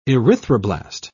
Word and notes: erythroblast: British English

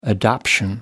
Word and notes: adoption: American English

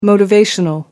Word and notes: motivational: American English

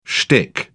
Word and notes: shtick: British English

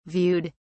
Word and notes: viewed: British English

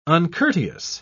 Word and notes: uncourteous: British English

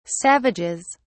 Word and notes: savages: British English

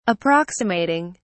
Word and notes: approximating: British English

